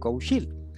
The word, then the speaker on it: Cougil: Y es curioso porque además en Galicia muchas veces que la G se pronuncia X. Entonces el apellido, mi apellido se acaba nombrando como Nacho Cougil, con una X.